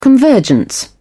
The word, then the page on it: convergence: British English